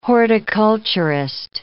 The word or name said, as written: horticulturist